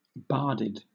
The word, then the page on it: barded: British English